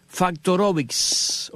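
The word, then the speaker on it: Faktorowicz: Y esto de Factorovich con K y la terminación VIX, que yo acabo de pronunciarla a la manera eslava, porque la W en el mundo eslavo suena como V, pero en el mundo nuestro sería VIX.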